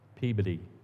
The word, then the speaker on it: Peabody: "Peabody," I say. "Peabody," they say, "because it's spelled Peabody." And they laugh, and it feels like every one of them has the same laugh. "Yes, I know, but that's not how it's pronounced," I say.